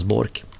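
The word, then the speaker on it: Boork: Leif Bork, uttalas Bork, är tränare för damernas landslag i hockey.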